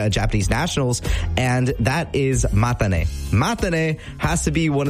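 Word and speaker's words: mata ne (またね): It's pronounced mata, M-A-T-A, mata, and then ne, N-E, mata ne, mata ne.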